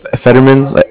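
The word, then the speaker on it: Federman: It's spelled B-R-E-N-D-A-N. Brendan. What's your name? – Fetterman. F like Frank. E-D-E-R-M-A-N.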